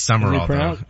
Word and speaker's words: Sumrall: He became part of, what's S-U-M-R-A-L-L. – I know, it's weird. It is Summerall. – Is it pronounced Summerall? – Yeah. – I feel like there's a letter missing. – It is. It's not spelled right, but that's how it's pronounced.